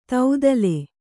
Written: ತೌದಲೆ

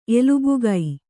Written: ಎಲುಬುಗೈ

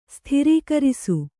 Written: ಸೂತ್ರೀಕರಿಸು

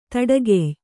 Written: ದಡಿಗೈಯ

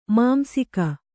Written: ಮಾಂಸಿಕ

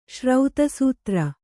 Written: ಶ್ರೌತಸೂತ್ರ

